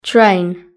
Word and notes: train: British English

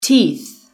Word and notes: teeth: British English

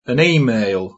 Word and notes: an email: British English

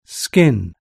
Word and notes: skin: British English

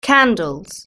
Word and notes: candles: British English

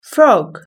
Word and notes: frog: British English

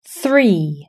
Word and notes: three: British English

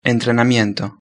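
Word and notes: entrenamiento: Spanish (Spain)

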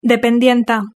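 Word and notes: dependienta: Spanish (Spain)

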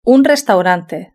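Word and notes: un restaurante: Spanish (Spain)

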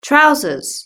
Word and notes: trousers: British English

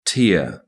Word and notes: tear: British English

